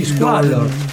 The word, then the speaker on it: Squallor: Degli Squallor. – Detto bene? – Sì, sì, hai detto benissimo.